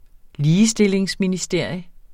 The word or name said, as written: ligestillingsministerium